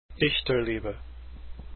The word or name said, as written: Dichterliebe